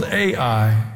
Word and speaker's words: Ai: It's called Ai. It's spelled Ai.